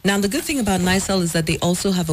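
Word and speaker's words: NIRSAL: Now, Nysel is actually the one that's in charge of the Axmis Microfinance.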